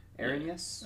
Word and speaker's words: Erinyes: Erinyes. Oh, it's, Yeah, it's literally spelled Aaron, E R I N, and then yes.